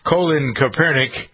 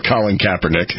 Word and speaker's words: Colin Kaepernick: Colin Kaepernick. I always try to play with that, but I think that's really how to pronounce his name. – Or Colin Kaepernick.